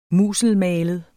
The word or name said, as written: musselmalet